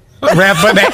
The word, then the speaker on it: Rathbone: Rathbone.